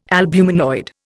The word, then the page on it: albuminoid: Indian